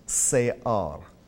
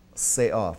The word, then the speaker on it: se'ar: And that word is pronounced se'ar, se'ar, from right to left, se'ar.